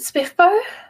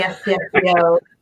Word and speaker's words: SPFBO: Yeah, I pronounce it SPIFBO. So. – Good. Glad to hear that. All right, how about you? – Well, SPIFBO is just painful on my ears. It's definitely SPFBO. Sorry, I can't help that.